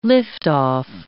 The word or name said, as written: liftoff